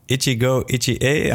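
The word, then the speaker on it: Ichigo Ichie (一期一会): So uh, Ichigo Ichie, am I am I pronouncing that right? Perfect. Perfect.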